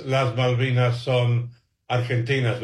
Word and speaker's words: Las Malvinas son Argentinas: Last night, the players go out and celebrate onto the pitch with a banner that reads, Las Malvinas son Argentinas, which, by the way, you pronounced beautifully, John.